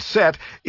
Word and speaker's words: SET: SET, usually pronounced as the word SET,